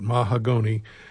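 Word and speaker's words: Mahagonny: If you were a theater major, that's how you pronounced it, Mahagoni.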